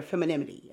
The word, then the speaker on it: femininity: So, and that definitely can help that child identify or that daughter identify with their own feminine, you know, their femininity. Am I saying it right? – Femininity. – I think femininity. Yeah, that's a tricky word.